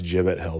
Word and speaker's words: Gibbet Hill: It's pronounced Gibbet Hill, but I can't say gibbet. It sounds weird.